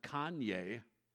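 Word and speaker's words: Konya: The first 7 verses outline the revival that happened in Iconium, which is modern Kanye. That's how you pronounced it. You mean like Kanye West? Yeah, that's where his name comes from.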